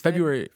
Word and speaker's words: February: I think it's February. – February. No, I don't think I am. – You're not supposed to pronounce the R. It's just in there.